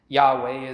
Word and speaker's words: Yahweh: He declares the name, and this is Yahweh is what it is in Hebrew, as far as we know how to pronounce it.